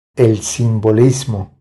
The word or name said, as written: El simbolismo